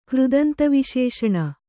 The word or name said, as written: ಕೃದಂತವಿಶೇಷಣ